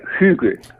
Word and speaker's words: Hygge: No, bueno, se pronuncia Hygge.